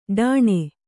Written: ಢಾಣೆ

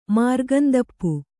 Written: ಮಾರ್ಗಂದಪ್ಪು

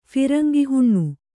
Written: ಫಿರಂಗಿಹುಣ್ಣು

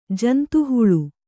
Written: ಜಂತುಹುಳು

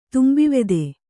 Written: ತುಂಬಿವೆದೆ

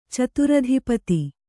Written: ಚತುರಧಿಪತಿ

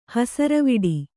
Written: ಹೆಸರುವಡೆ

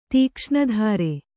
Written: ತೀಕ್ಷ್ಣಧಾರೆ

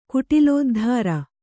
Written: ಕುಟಿಲೋದ್ಧಾರ

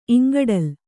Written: ಇಂಗಡಲು

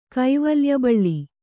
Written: ಕೈವಲ್ಯಬಳ್ಳಿ

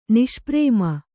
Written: ನಿಷ್ಪ್ರೇಮ

